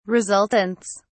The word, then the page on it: resultants: British English